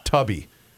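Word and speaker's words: Tubi: The correct pronunciation when any of us are using it is called Tubby. That's Tubby.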